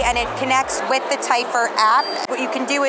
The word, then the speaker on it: Typhur: Typhur? – Yes.